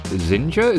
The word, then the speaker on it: Zinja: Today I'm delighted to speak with Eric Wilson, the CEO of Zinja. Is that how I say it, Eric? – That's exactly how you say it, just like Ninja.